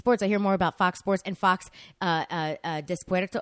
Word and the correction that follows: Deportes: How do you say Deportes? – Deportes. – Yeah. It's Fox. Fox Deportes.